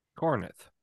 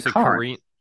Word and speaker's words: Corinth: Like, for instance, how would you say this? Cornith. – Cornith? – No, sorry, C-O-R-I-N-T-H. Sorry, I spelled that wrong. – Corinth. – Corinth. Yeah, that's how most people would say it.